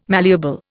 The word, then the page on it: malleable: Indian